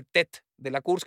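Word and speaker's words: Tête de la Course: que luego ya entendí que ese TET de la Course, que es, digamos, la cabeza del recorrido o el líder de la etapa, es TET de la Course, pero se escribe TT, y le decíamos el pinche TT, llegaba a Alcalá y le decíamos, pinche te qué pedo.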